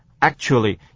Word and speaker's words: actually: 再来是 C 段， C 段先看一个简单的字，也就是啊头一个字 Emily 说的第一句话的第一个字 actually， actually 啊 C 不发音 actually，也被 actually 没有那么多的的声音 actually actually。